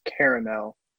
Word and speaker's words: caramel: Yeah, because like in Europe or in the UK, I think it's caramel. – Yeah. – But then us in the US, Us in the U.S., we're lazy, so we take out a syllable.